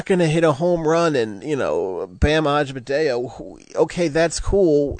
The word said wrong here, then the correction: Adebayo: Yeah, added like at least 3 syllables there. It's Bam Adebayo.